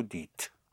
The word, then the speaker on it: dites: So these are the three exceptions for vous, where you have, where you don't have an E-Z, E-Z, but a one-syllable T-E-S, where you sound the T, one syllable for the T. I call them the three QTs. They're very cute, really. Et, fet, and dit.